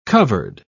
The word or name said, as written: covered